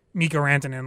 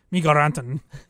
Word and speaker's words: Rantanen: The Nathan McKinnon, Gabriel Landeskog, Mikko Rantanen line, or as Pierre Maguire says, Mikko Rantanen. – Mikko Rantanen. – Tried so hard to get the Finnish pronunciation.